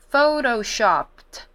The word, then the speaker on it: photoshopped: 第三个要注意到的呢，就是我们常用的 ps photoshop，那它这边要加 ed，我们一样把后面那个 ed 的音念成 t 的音，然后念成 photo，而不是 photo， D 的音， photoshopped，然后再把那个 p 的音小一点，把它降低一点， photoshopped。